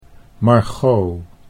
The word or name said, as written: Margot